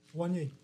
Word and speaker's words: Wanye: Wayne... – Wanye McCoy. – Oh, I didn't put the N in it.